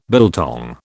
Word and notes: biltong: British English

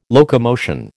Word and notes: locomotion: American English

